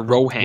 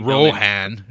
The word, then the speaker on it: Rohan: Are you saying Rowan or Rohan? – Well, it's R-O-H-A-N. – So and Rohan. – Rowan. – Yeah. Correct. Yeah. – But there's like W. Yeah, they be Rohan.